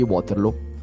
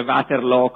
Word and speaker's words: Waterloo: La battaglia di cui parliamo stasera è una battaglia famosissima, anche se probabilmente non siamo d'accordo su come pronunciarne il nome. Io tendo a dire Waterloo, come sta scritto, che non è neanche tanto diverso da come lo pronunciano i fiamminghi.